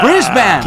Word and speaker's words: Brisbane: Ah, como é que é o nome da Brisbane. – O que, que é isso? – Ah, é, Brisbane. essa é a pronúncia, na Austrália.